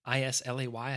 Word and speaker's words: Islay: It's straight bourbon whiskey that is finished in French oak and is it Islay or Islay? Islay.